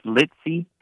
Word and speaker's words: Leitzsey: And it's like it sounds, I guess, Leitzsey, but boy, there's a Z.